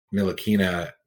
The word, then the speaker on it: Ntilikina: I think Frank Ntilikina, tried to say that right. – Well, that was it.